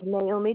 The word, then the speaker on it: Naomi: My name is Naomi Johnson.